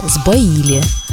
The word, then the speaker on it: сбоили: Да, если оставить только русский литературный язык, то останется сбоили у нас в именительном падеже у существительного «сбой», ударение на букву «о», но в глаголе оно будет на «и».